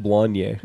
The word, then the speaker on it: Blaine: It's B-L-A-I-N-E, but it's pronounced Blanye.